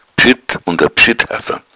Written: bschuett

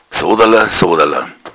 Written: sodala

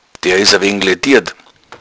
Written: laediert

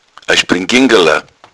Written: springingala

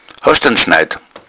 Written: schneid

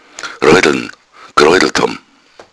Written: roideln

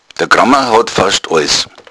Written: kramer

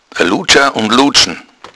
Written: ludscha

